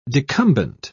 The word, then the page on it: decumbent: British English